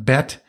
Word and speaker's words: Bet: So in Hebrew, the letter B is pronounced Bet, and it also stands for the number two in Hebrew.